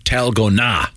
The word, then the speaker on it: dalgona: And it's apparently pronounced with a T sound, Talgona.